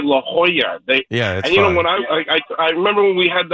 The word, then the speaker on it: La Jolla: And then Dave Sims texts me and say, "Hey, it's pronounced La Jolla, like the Georgetown Hoyas."